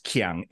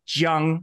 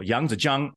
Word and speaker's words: Yangzijiang: But if you want to say K-I-A-N-G on Deep Space Nine is river, but it's not pronounced Qiang. It's Jiang. So it's a J sound.